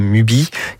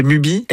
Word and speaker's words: Mubi: Je pense aussi à une plateforme comme Mubi, qui est payante, mais pas très chère, et qui diffuse des vieux films.